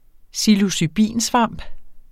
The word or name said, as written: psilocybinsvamp